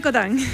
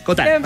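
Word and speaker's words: Cottin: Marianne Cotán. – Marianne Cotán, porque no estaba seguro si se pronunciaba Cotín, pero Cotán. – Francés, Cotán.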